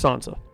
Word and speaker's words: Sansa: Are we going Sansa or Sansa? – It's Sansa. – Sansa. – Okay. Yeah, it's Sansa. So what is that, a long A? Sansa? – Yeah, long A. Like the Long Night.